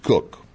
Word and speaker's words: Koch: It's spelled K-O-C-H, but we're told it's pronounced Cook.